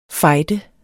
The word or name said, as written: fighter